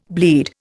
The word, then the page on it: bleed: Indian